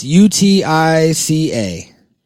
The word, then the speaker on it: Utica: I'm probably not saying that right. Ukiah? It's U T I C A. Utica. Utica, Kansas.